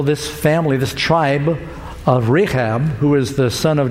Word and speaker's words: Rechab: Thus, because our father, Rechab, is how you say it.